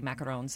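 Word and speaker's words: macaron: And you are also recommending, depending on your pronunciation, French macaroons or French macarons? – Macarons. Yes.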